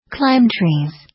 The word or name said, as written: climb trees